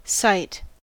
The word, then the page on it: sight: American English